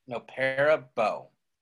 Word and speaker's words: nopperabo: Okay, N-O-P-P-E-R-A-B-O. – Okay. – No para bow.